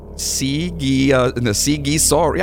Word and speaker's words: Sighișoara: Vlad was born in the town of Sighișoara. Sighișoara. Yeah, I said it right the first time.